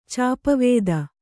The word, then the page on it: ಚಾಪವೇದ: kannada